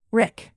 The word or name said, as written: rick